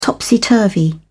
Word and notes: topsy turvy: British English

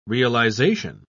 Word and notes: realisation: British English

